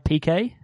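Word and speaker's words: Piqué: Gerald Piquet, the Shakira's husband.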